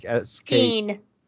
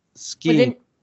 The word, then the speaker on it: Skeen: It's pronounced Scheme, right? Like Skeen. – Skeen. – With an N.